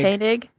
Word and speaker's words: Koenig: Koenig.